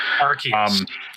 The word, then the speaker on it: Arceus: Arceus, Acreus, whatever. – Arceus. – Arceus, yeah. I think that's what we decided on. – That is the official pronunciation.